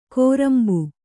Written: ಕೋರಂಬು

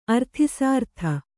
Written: ಅರ್ಥಿಸಾರ್ಥ

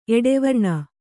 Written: ಎಡೆವರ್ಣ

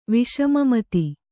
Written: ವಿಷಮಮತಿ

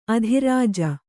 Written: ಅದ್ರಿಜೆ

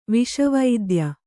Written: ವಿಷವೈದ್ಯ

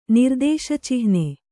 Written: ನಿರ್ದೇಶಚಿಹ್ನೆ

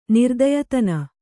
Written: ನಿರ್ದಯತನ

